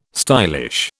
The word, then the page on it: stylish: British English